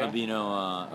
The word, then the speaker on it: Abeno: And Abeno, is that how you pronounce the area? – Abeno, Abeno.